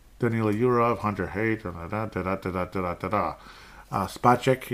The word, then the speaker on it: Spacek: Spacek, it's not Spacek, so, David Spacek, he's Space K, you know, like Space X, he's Space K, okay? That's how you pronounce it.